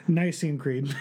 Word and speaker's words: Nicene Creed: if you've ever said the Nicene Creed, is that right? I don't know how to say it. – Just quick and confident. – Yeah, Nicene Creed.